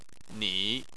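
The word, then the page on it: ni: tone 3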